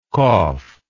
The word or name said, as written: koph